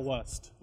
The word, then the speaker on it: wast: Is this wast or west? I'm so thou, confused. Thou wast. Like wust? Was, but wast.